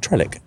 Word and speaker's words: Trelleck: Is that how it's pronounced, though? – Well, interestingly or not, it's the most differently spelled town in the UK. So it's, I spell it T-R-E-L-L-E-C-K, and there's another 23 spellings of it, according to the signs around here. – Right. – But well, it's Trellick.